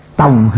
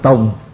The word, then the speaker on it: tòng: Đây có hữu vấn: nhất thiết chúng sinh tòng hà như lai, bách niên chi hậu tòng hà như khứ. Đó. Nhất thiết chúng sinh tòng hà như lai, mình ở trong Nam đọc là tùng, phải không? Ở đây thì viết theo ngoài Bắc.